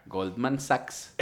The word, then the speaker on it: Goldman Sachs: Goldman Sachs.